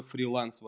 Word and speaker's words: фриланс: Это очень трогательная история, которая начинается с того, что а, давайте поймем, что такое фриланс вообще. А, ударение на, на А.